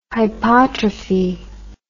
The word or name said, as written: hypotrophy